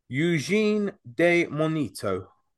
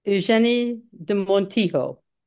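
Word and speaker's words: Eugénie de Montijo: Eugène de Monito. – Let's try it at Eugenie de Montijo.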